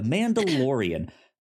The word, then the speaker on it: Mandalorian: I was about to say, did I say that wrong? The Mandalorian. Yeah, that is coming up at the start of March.